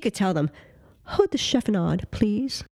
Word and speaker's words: chiffonade: Chiffonade. – Barely even. – Chiffonade. – Chiffonade? – Chiffonade.